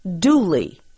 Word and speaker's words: Dula: An example is the story of a young man whose last name was spelled D-U-L-A, but pronounced Dooley.